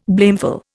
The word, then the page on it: blameful: Indian